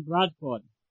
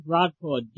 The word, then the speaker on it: Bradford: This is Don Bradford. – Would you spell that for me, please? – Don Bradford. D-O-N-B-R-A-D-F-O-R-D. – D-O-N-B-R-A-D-F-O-R-D. O-R-D.